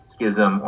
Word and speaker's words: schism: Just FYI, one of my favorite things to gripe about is that nobody can decide whether the word S-C-H-I-S-M should be pronounced schism or schism. It's pronounced both ways all the time. I always thought it was pronounced schism and then just about every professor I had in grad school said schism.